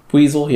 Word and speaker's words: Buizel: All right, so I hopped down over there to run over there, and then there was a, how do you pronounce it? Is it Buizel? – Buizel, yeah.